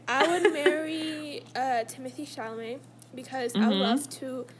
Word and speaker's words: Chalamet: I have Timothy Chalamet, is that how you pronounce his name? – Yeah.